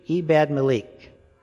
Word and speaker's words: Ebed-Melech: It's Ebed, long E, me, long E, leek, Ebed-Melech.